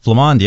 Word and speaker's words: Flamand: Flamand, yeah. – Flamand, yeah. – Okay. Little French twist there.